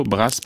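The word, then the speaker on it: Brás: Alors, je vais essayer de les prononcer. Bon Retiro, Brasse, Paris, je prononce correctement ? – Oui.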